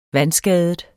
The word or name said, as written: vandskadet